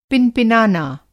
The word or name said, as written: पिनपिनाना